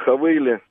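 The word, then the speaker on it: Haval: Вот сейчас езжу прекрасно на Хавейле, вот если правильно говорить, Хавейл называют. – Хавейл, они называют, да.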